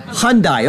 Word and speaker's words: Hyundai: How do you pronounce it? – Hyundai. – Okay, thank you.